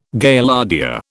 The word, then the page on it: Gaillardia: British English